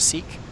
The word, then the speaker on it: seq: You pronounce that seek. Is that correct? Seq is seek. I'm never sure.